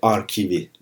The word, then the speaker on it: Arkiv: Felsefe Arkivi dergisinde ölümü hakkında çıkan yazı. Arkiv, arşiv yani Fransızca telaffuz değil Almanca telaffuzla. Felsefe Arkivi.